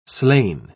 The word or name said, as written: slain